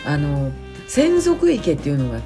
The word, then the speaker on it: 洗足池: 洗う足の池と書いて専属池と読む